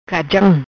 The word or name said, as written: Cajam